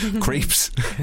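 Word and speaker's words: Krieps: Vicky Creeps or Cripes? – Creeps.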